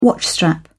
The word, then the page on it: watchstrap: British English